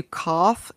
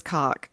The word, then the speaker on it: Koch: It's spelled K-O-C-H, German, and it's pronounced close to cough, except all phlegmy, and I'm not going to do that to y'all, so I'll just say cough. And the other option, I guess, is cock.